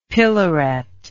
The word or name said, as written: pillaret